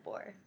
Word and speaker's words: Boer: And the only reason she really was visiting is because she wanted to increase Irish enlistment in the British Army during the Boer War. I wonder if I said that right. I should have looked that up beforehand. – I've always said Boer. – Boer? Okay. – Yeah.